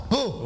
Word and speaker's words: Pau: Bah oui, Pau avec un P. – Alors Pau. – Oui, comme ça s'écrit, pareil.